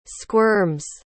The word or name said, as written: squirms